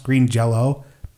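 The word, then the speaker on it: Green Jellÿ: I think actually it's written Green Jelly, but I think it's pronounced Green Jello.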